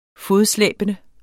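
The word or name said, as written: FODSLÆBENDE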